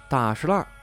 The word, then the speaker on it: 大栅栏: 大石烂位于东城区几条交错的胡同里。如果你在这片胡同里问大栅栏文体中心怎么走，别人会笑话你，因为大栅栏念作大石烂。